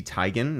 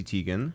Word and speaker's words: Teigen: It's Chrissy Teigen, not Chrissy Teigen. – Yeah. – I've been saying Teigen this whole time.